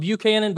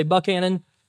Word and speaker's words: Buchanan: I know it looks like it's spelled Buchanan because it is, but they say Buchanan.